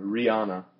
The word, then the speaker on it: Rihanna: H 그냥 묵음이라고 생각하면 돼. 그냥 Oh. – H 없는 것처럼 그냥 Rihanna.